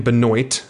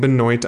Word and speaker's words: Benoit: He's actually a favorite versus Ryan Benoit. I'm saying Benoit. I'm well aware it's supposed to be pronounced Benoit, but he actually pronounces it Benoit, so I'm going to support him and go with Benoit.